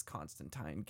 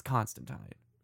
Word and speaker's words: Constantine: And yes, the actual pronunciation is Constantine. Get used to it. Gaiman and Alan Moore have corrected us all. It's Constantine.